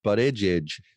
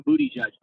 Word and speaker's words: Buttigieg: How do you say his name? – Buttigieg. – Okay, because I keep calling him Booty Judge.